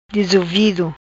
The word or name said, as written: Dizuvido